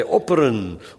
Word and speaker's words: opperen: U weet toch wat opperen is? Nee, dat is hetzelfde als offeren, alleen spreek je de F nu harder uit. De Duitsers kunnen weten het niet zeker, die zeggen, hebben het over opfer, doen het allebei, de P en de F.